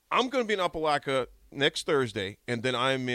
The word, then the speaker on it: Opelika: I'm in Opelika. Am I saying that right? – Yeah. – Opelika, Alabama.